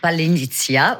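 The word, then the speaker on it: Palianytsia: et un que j'ai du mal à prononcer, qui s'appelle le Palinitsia. – Palinitsia, voilà, exactement.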